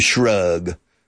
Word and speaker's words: shrug: To shrug. Yeah, to shrug your shoulders. Yeah, strange word, to shrug. S-H-R-U-G. Es como alfombra, rug, con SH delante.